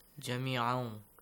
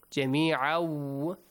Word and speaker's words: جميعا: جميعا جميعا. You extend the sound for two beats.